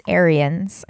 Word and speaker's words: Arians: No, Bruce Arenas. – Not that coach. – Oh, okay. – A different coach. The Buccaneers... Bruce Arians. I think that's how you say it. – Okay. – A-R-I-A-N-S.